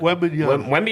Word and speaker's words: Wembanyama: Wembanyama is a nice shorthand.